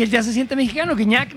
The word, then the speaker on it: Gignac: El paso perfecto de la selección mexicana ha sido tan arrollador que incluso el francés André-Pierre Gignac, así se pronuncia, ¿verdad? – Gignac. – Que no Gignac.